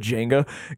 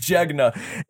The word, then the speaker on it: Jegna: We I wore my I've been saying it wrong for the longest time, but I always said Jenga. It's Jegna. It's an Ethiopian word for here, let me read it.